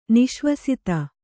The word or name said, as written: ನಿಶ್ವಸಿತ